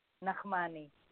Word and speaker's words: Nachmany: Michal Nachmany.